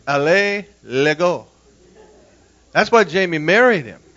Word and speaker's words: Alain Legault: And His first name is spelled A-L-A-I-N, and his last name is spelled L-E-G-A-U-L-T. It's pronounced Alain Legault. L-A like is Los Angeles, L-A, Legault.